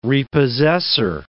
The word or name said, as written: repossessor